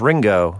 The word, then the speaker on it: Ringo: Not like with, now his name is Ringo. – Like, you know how to spell it. – Rhymes with bingo.